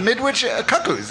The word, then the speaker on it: Midwich Cuckoos: And I don't have that in front of me, so I cannot verify pronunciation. – The Midwich Cuckoos, that's it, 100%.